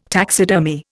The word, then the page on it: taxidermy: Indian